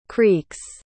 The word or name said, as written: creeks